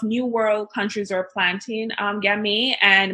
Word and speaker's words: Gamay: Right up there with a Pinot Noir, a Gamay. And that pairs really well with oxtail. – So Gamay? So spell that for us. – G-A-M-A-Y.